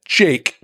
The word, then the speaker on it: JVKE: So there's a tune that everyone needs to Google because it's gorgeous, called "Golden Hour" by a guy named Jake, which is spelled J-V-K-E because 2023.